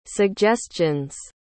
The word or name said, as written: suggestions